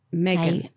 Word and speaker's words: Meghan: They pronounce Meghan, Meghan. – Meghan. – Meghan over there.